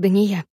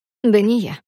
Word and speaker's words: Дания: Говорю, «Меня зовут Дания». – «Ударение на «я»?» «Да». «Я же так и сказала. Дания». Акцентирую ударение, чтобы он понял.